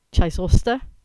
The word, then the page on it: Chysauster: English